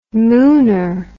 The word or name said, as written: mooner